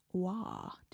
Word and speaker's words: Roy: There's a lot of Roy hockey players, and some of them are pronounced Wah. Did you know that? – They're from like Crispin. – Quebec. – Crispin Wah. – How do you get Wah from Roy? – What? – It's a French attitude. They have like a mouth thing.